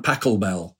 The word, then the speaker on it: Pachelbel: And on there he did a kind of set of versions of an old canon in D major, Johann Pachelbel.